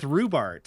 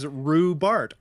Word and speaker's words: Rubart: That's same with Rubart, because Rubart, R-U-B-A-R-T, to me seems very simple.